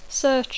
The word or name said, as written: search